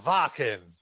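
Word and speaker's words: Wacken: It's pronounced Vakin.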